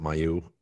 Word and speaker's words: Mailloux: And I'm not sure is it Mailloux. – I yeah, think I think I think you're right. Yeah. Is how you pronounce it.